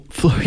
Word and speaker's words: Flores: To Flores. – Yes. – How you pronounce it? – Oh, that's how we pronounce it.